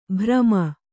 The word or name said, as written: ಭ್ರಮ